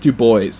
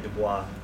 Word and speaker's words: Du Bois: I took a class and that's how it's pronounced. – That's how it's pronounced, really? – It's pronounced Du Bois, yes.